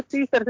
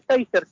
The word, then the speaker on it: Taser: no, se, no se dice teaser, se dice taser.